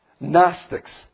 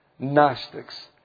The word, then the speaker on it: Gnostics: Gnostics. Gnostics. And it's spelled with the letter G. This is number 1. G-N-O-S-T-I-C-S. Gnostics.